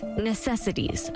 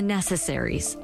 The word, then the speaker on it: necessaries: Whenever I report on these types of cases, I get messages from people saying I'm mispronouncing the charge, suggesting that it's necessities of life. It is, in fact, failing to provide the necessaries of life.